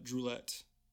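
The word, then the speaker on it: Druillettes: Jesuit fathers, and uh, it'd be Gabriel uh, Droulet because the S isn't pronounced.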